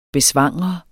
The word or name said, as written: BESVANGRET